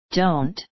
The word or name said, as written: don't